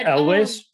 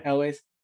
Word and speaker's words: Elwes: How do you say that? – Elwes. Yeah, Elwes.